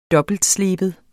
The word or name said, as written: dobbeltsleben